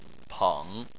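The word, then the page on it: peng3: tone 3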